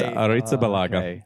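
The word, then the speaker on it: Arrizabalaga: It's Aretha Balaga. It's A-R-R-I-Z-A-B-A-L-A-G-A.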